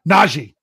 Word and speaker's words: Najee: Javier says Najee Harris. Najee. You're right. Yeah, I'm saying it wrong.